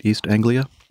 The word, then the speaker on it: East Anglia: Did I say that right? East Anglia? – Yeah.